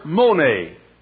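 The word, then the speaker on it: mone (μονή): The word abide there is the word mone. We'd spell it M O N A or M O N short E. Mone.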